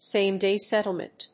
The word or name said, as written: same day settlement